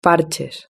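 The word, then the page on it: parches: European Spanish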